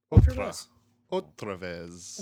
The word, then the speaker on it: otra vez: Ortavez. – Ortavez. – Well, it's spelled Orta. – Is it really? No, O-T-R-A. Otra. – Otra vez.